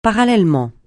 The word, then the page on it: parallèlement: francais